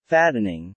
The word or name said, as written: Fattening